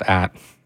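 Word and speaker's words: @: At in there, that's right, that band is just an at symbol. It's pronounced at.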